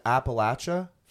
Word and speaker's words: Appalachia: And Appalachia? Did I say it right? – Yeah, you did.